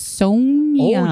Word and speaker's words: Sonja: I am good, and I'm going to correct you on my name. It's Sonja. – Oh, you've got a long O. Okay. – Like Red Sonja.